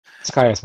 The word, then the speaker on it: Tekias: The most common guess is Tekias. – Tekias, huh? – Yeah, I always pronounce it Tekias, so. – We'll just call you Tech, right? – Tekias works.